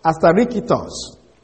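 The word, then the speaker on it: asterikitos (ἀστήρικτος): Now, the word unstable, because he described them as ignorant and unstable, the word unstable then comes from the Greek word asterikitos. Asterikitos is spelled A-S-T-E R-I-K-T-O-S, asterikitos.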